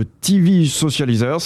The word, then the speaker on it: TV Socializers: Alors Olivier Goulet, il y a 4 profils de TV Socializers, si je prononce bien, c'est bien ça ? – Oui, tout à fait.